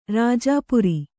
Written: ರಾಜಾಪುರಿ

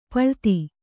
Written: ಪಳಿತ

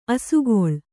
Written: ಅಸಿಗಲ್ಲು

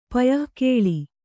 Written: ಪಯಃಕೇಳಿ

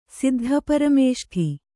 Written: ಸಿದ್ಧಪರಮೇಷ್ಠಿ

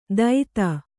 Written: ದೈತ

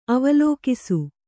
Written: ಅವಲೋಕಿಸು

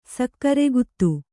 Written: ಸಕ್ಕರೆಗುತ್ತು

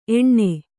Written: ಎಣ್ಣೆ

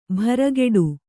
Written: ಭ್ರಕುಟಿ

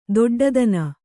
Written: ದೊಡ್ಡಿತನ

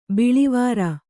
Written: ಬಳ್ಳಿವರಿ

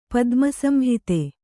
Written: ಪದ್ಮಸಂಹಿತೆ

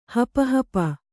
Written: ಹಪಹಪಿ

